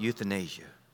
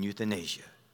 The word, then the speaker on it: euthanasia: What about euthanasia? By the way, when you Google that, there's two ways to say that name. Euthanasia, you use it over across the waters, and euthanasia. You can pronounce it either way.